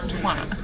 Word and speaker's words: twat: Because the word twat is not pronounced that way in England. It is pronounced twat.